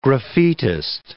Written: graffitist

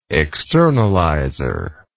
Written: externalizer

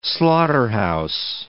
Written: slaughterhouse

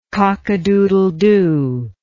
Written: cockadoodledoo

